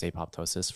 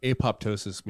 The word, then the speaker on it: apoptosis: Apoptosis means cell death. – Mm, that's cool. – Yeah. – Cool word for that. – It's also known as apoptosis if you don't pronounce the other P.